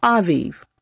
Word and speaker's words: Abib: God changed the name from Adar to the new name he gave it, A B I B. It is pronounced Aviv.